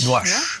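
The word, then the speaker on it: Nuages: Nuage, Nuage. Ne? wird so ausgesprochen.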